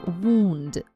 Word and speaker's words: wound: Wound. Wound. Это слово все всегда неправильно произносят. Пишется оно W-O-U-N-D. W-O-U-N-D. And произносится wound. Wound.